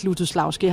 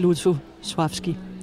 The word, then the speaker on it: Lutosławski: Altså de fleste her på bagscenen siger faktisk Lutosławski, har jeg lagt mærke til. Men altså når jeg er i selskab med polakker, så siger jeg det i hvert fald ikke. Fordi der hedder det Lutosławski. – Der er den der lille streg over det andet L, som betyder, at man skal udtale det mere som V.